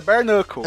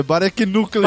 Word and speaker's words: Bare Knuckle: Você me deixou tão nervoso que eu falei errado como eu falava quando eu tinha 3 anos. É Bare Knuckle. – É, Barak Nuka.